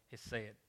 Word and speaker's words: hesed: The first word I want to talk about is the word hesed or hesed. People pronounce it different words. I'm just going to say hesed. Hesed is a Hebrew word that is often translated in the Old Testament as mercy, kindness, loving kindness, goodness, and favor.